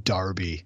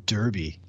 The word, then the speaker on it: Derby: Yeah, and I'm also happy with your pronunciation of Derby instead of Derby. – Yeah, I speak British more than I speak, you know, American.